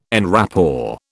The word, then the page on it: en rapport: British English